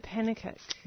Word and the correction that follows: Pennicuik: Sue Pennicuik, it's pronounced. Pennicuik.